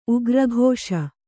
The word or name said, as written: ಉಗ್ರಾಕ್ಷ